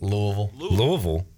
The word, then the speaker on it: Louisville: Louisville. Because the S is silent.